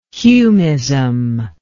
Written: humism

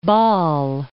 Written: Basle

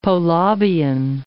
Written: Polabian

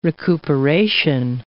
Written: recuperation

